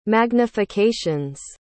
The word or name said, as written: magnifications